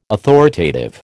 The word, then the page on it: authoritative: American English